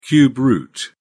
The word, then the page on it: cube root: British English